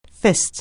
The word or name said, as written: fist